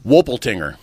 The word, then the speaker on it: Wolpertinger: How do you say it again? – Wolpertinger. – Can you spell it? – W-O-L-P-E-R-T-I-N-G-E-R. – Okay.